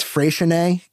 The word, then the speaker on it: Freixenet: It's Freixenet Cava, Freixenet. – Now, do you know how to pronounce it? – I do. – Okay, yes. – Great. – Freixenet is the, as far as I know, the correct pronunciation.